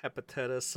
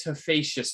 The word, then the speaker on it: Hephaestus: And then we get into the hepatitis airsoft. – How do you pronounce it? – How do you pronounce that again? – It's Hephaestus, I believe. It's the Greek Hephaestus.